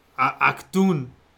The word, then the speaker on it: Achtung: Achtung Baby? – Achtung.